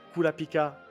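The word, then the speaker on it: Kurapika: que sont Kulapika, Leorio et Kilua. Et oui, parce que moi, le R, je le prononce comme un L, parce qu'en japonais, les R se roulent.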